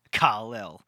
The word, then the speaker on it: Kal-El: Kal-El. Like, that's how we pronounce it. – Oh, come on. Come on. – Everyone knows it. That's the correct pronunciation.